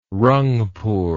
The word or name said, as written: rangpur